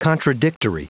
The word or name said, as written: contradictory